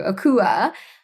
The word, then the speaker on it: Akua: So if you need to remember Akua, it's like aqua for water, but with a K for kelp. And then we pronounce it like the Hawaiians do, Akua, which is a Polynesian term that refers to like spirits and goddesses that, you know, protect the ocean.